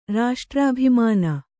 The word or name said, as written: ರಾಷ್ಟ್ರಾಭಿಮಾನ